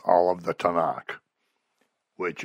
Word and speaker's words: Tanakh: And since Hebrew doesn't have any vowels, you can pretty much put whatever vowels you want in, but it's a set pronunciation, and it's Tanakh.